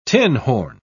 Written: tinhorn

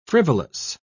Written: frivolous

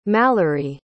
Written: malorie

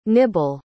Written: nibal

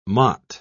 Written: mott

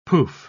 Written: pouf

